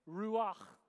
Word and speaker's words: ruach: So, try and say it ruach. A little more on the back of the throat.